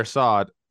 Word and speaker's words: Assad: Barrios versus Assad. I don't know if I'm saying that correctly. – Yeah, I apologize. – It is. Yeah, you are. – Javier Assad.